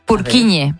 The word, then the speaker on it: Purkinje: Es que se escribe, en español sería Purkinje. – Purkinje. – Pero Purkinje es en checo, que es por porque es el apellido del descubridor,